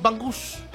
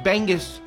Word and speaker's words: bangus: fried bangus. That's how you pronounce it, bangus. My mama's trying to correct me. I was saying bangus. No, bangus. Bangus.